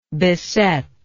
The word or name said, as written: besetter